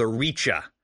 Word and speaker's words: Rice: So in August of 89, Garman Lord is rethinking things, and he founds an umbrella organization called the Richa, spelled like the word rice, but it's Old English for kingdom.